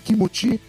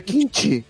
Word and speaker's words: kimchi: Kimchi? É, é coreano? – É, é, é, que na pronúncia pro japonês fica kimuti E no Sim. coreano é kimchi tá ligado?